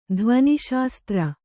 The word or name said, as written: ಧ್ವನಿಶಾಸ್ತ್ರ